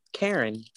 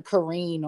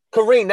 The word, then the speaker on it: Kareen: I think it's his name was called, Karen. Like, it's like a Karen. – They pronounce it differently than that. It's like Kareen or something. – Kareen, that sounds right. That sits right in my spirit. – Yeah, I'll take Kareen.